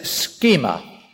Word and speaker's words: schema: One word is schema. If you're writing that in English spelling, it would be S-C-H-E, that's a long E, so you put a little line over the top to show you it's long, S-C-H-E-M-A, schema. The other word is morphe, M-O-R-P-H, long E, again with a little line on the top to show it's long.